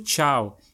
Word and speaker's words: ciao: Com certeza, a maior e mais marcante delas é o uso do ciao, que aqui preserva uma pronúncia muito próxima à saudação italiana, ainda que tenha uma escrita bem diferente. Enquanto no italiano essa palavra se escreve com C-I-A-O, no português escrevemos com T-C-H-A-U, uma grafia, quer dizer, uma forma de escrever que não é nada comum em outras palavras do português brasileiro.